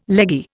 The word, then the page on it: leggy: Indian